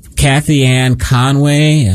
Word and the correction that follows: Kellyanne Conway: I think I got it correctly, Trump's old campaign manager, Kellyanne Conway, got in contact with him.